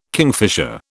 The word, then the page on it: kingfisher: British English